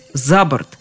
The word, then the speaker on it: за борт: Вот тут-то надо запомнить, что ударение обычно перемещается на предлог «выбросить» – «за борт», «поднять» или «взять» – «на борт», «идти» – «борт» – «оборт».